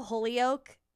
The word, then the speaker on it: Holyoak: Um also I like how Holyoak is one word and that's how you say it, but like it, it, that's not how you think you're going to say it.